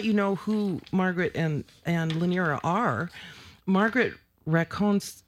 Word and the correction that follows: Rancone: Is it Racone? – Rancone. – Rancone. – Yeah. – Is it Italian? – An Italian man told me that's really? – How you pronounce it.